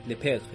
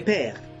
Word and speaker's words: Le Père: Le Le Père, no sé cómo se dice el padre en francés, pero si alguien sabe. Le Père.